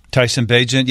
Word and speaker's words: Bagent: Is that how you say his name, Bagent? – Tyson Bagent, yeah. B-A-G. It looks like B-A-G-E-N-T.